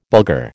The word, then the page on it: Bulgar: American English